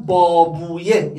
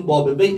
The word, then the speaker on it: بابویه: حالا به غلط ما میگیم بابه بی، اشکال هم نداره، وقتی بابویه بیاره تو زبان عربی وی تلفظ میشه. سیبویه نه سیبه بی، مسکویه نه مسکوی، شیرویه نه شیروی.